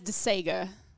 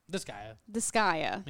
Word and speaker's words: Disgaea: I've always read it as DeSega. – No. It's Disgaea. – I think that's my southern brain reading it like DeSega. – Yeah. Disgaea.